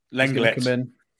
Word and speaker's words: Lenglet: They're predicting that Clement Lenglet is going to come in, or Longley, however we say his name. – Lenglet. – Lenglet, no.